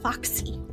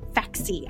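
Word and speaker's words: Faxi: Foxy. – Yeah. – Faxy. I hope I'm saying this correctly. It's F-A-X-I.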